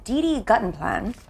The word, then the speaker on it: DD Guttenplan: We have Didi Guttenplan, right? – Right.